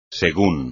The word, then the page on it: según: espagnol